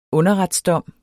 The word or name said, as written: underretsdom